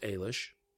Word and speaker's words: Eilis: Now, it's spelled E-I-L-I-S, but it's actually pronounced Eilish.